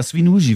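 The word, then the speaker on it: Świnoujście: Aber Swinuji wird es, glaube ich, ausgesprochen.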